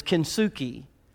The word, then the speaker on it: Kintsugi: And it's called Kintsugi. And I checked that out. I'm saying it right. I know I don't say a lot of other words right. But it's Kintsugi. And what it is, it's a Japanese form of art.